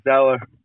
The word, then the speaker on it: Zoeller: Zoeller? Is that it? Isn't it Zoeller? Zoeller. It's spelled that way. S-O-Z-O-E-L-L-E-R. Isn't that Zoeller? – Yeah, a little Zeller, but...